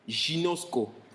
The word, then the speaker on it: ginosko: Le tout premier niveau de connaissance dans la Bible, c'est le mot grec que l'on prononce ginosko, qui est la connaissance de base. Ginosko, ça se prononce comme ça.